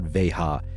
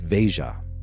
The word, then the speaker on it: Veja: By the way, you pronounce the J. It's not an H. It's not Veja. It's Veja.